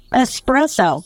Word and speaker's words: espresso: And I didn't know that espresso was not, that isn't how you pronounce it. There's no X. It's right. – It's espresso.